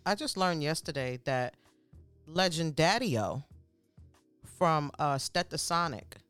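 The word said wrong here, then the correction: Stetsasonic: But I said Steta. Right. – Yeah, Stets.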